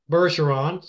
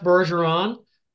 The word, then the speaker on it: Bergeron: And my email address is F as in my first name, my last name, Bergeron. So it's F Bergeron, the number 2. – Okay, just a second now. People don't know you're French, and they don't know that's a soft G.